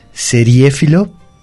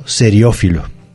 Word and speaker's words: seriéfilo: Es seriéfilo y no seriófilo. Seriéfilo es la palabra, se escribe con acento en la segunda E y es un solo término, seriéfilo.